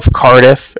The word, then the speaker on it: Cardiff: And I was calling with the Bank of Cardiff, and that's spelled C-A-R-D-I-F-F, as in Frank, so it's like car and then different.